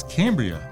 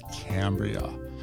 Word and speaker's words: Cambria: Cambria, which some people pronounce as Cambria, but I think we're going to go with Cambria.